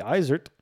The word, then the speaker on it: Isert: Because I pronounce it with a Z, but is that what you mean? – I think it should be pronounced with a Z, yeah, because it's German, so it should be Isert.